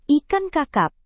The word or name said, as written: ikan kakap